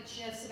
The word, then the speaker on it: GIFs: You will find literally pages and pages of videos, GIFs, this is pronounced GIFs, sorry, and images of how to properly load a dishwasher.